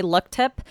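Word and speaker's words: Luk Thep: So it's spelled L U K space T H E P and it's Thai. So I'm just going to say luck tip, but I was already pronouncing amulet wrong accord- uh, reportedly.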